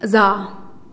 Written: zaa

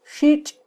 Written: xitx

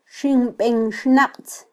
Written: ximbenxnaqtz

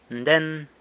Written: nden

